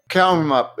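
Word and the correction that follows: Cowaramup: Quarrumup. If you imagine it has a Q at the start, it makes it easier.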